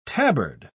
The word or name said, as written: tabard